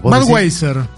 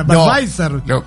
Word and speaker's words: Budweiser: Budweiser. – Budweiser. – Bueno, para mí es una marca americana y se pronuncia W, no B. – Claro, por eso... Sería alemana, Badweiser.